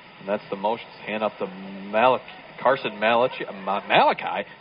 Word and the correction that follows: Mulcahy: Excuse me, how do you think you pronounce this? – Mulcahy. – Like, I'll ask the guy that's been doing this for a few years. – Father Mulcahy. – Well, I screwed you up, too, when I put the spotter board sheet together for you. I'll take a hit for that, because I had pronunciations on the other sheet. – Yeah, it says Mulcahy.